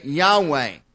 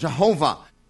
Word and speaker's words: Yahweh: We would say Yahweh. Many times it's pronounced Jehovah in English,